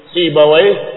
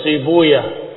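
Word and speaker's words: سيبويه: seperti Sibawai mereka menyebutnya Sibuyah karena menghindari kata waih yang katanya waih maknanya jelek sehingga para ulama yang diakhiri kata waih mereka rubah didomahkan di awalnya.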